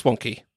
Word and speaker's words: Wonkhe: Also, it's pronounced wonky.